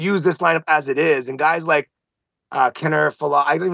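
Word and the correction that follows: Kiner-Falefa: Isaiah Kiner Falefa.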